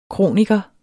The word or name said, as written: kroniker